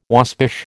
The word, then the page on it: waspish: American English